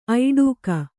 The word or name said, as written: ಐಡೂಕ